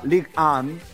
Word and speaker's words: Ligue 1: Pero bueno, en definitiva, va a estar jugando en la Liga 1, la Ligue 1, para seguir con la pronunciación en francés.